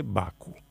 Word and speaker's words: Bakú: Aunque se escribe con acento en la U, todo el mundo dice Bakú.